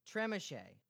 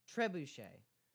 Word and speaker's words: Trebuchet: Trebuchet. – Excuse me, Trucker Champ? – Trebuchet. – Trevor Champ? – The terrible font. Trebuchet is a terrible font. – Tremachet. – Trebuchet. – Trebuchet.